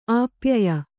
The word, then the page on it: ಆಪ್ಯಾಯ: āpyāya